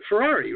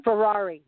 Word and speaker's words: Ferrari: Also, a lot of customers bought John Michael Frizzari. I'm probably saying his name wrong, aren't I? Ferrari, right? – Ferrari. – Like the car. – Ferrari, just like the car.